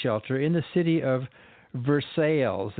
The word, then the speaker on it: Versailles: That's how they pronounce it. Versailles, Kentucky. – But it's spelled like Versailles in France. – Well, it's spelled like Versailles, but they can say it the way they want.